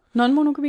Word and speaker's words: non-monogami: Men jeg er nødt til at spørge dig om, hvad non-monogami er rigtigt udtalt? – Ja. Non-monogami, ja. – Non-monogami. Det er flot. Tak for hjælpen. – Det er bare lige non for en monogami.